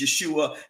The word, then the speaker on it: Yeshua: For those of you who are not familiar with our ministry, you may hear me use Yeshua and Jesus. This is because Yeshua is the pronunciation of his name in Hebrew.